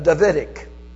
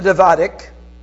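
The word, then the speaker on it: Davidka: The Israelis had a tremendously noisy mortar called a Davidic. Now I'm spelling a D-A-V-I-D-K-A. So I don't know if that's the pronunciation, but that's how I'm pronouncing it. David with a K-A on the end of it.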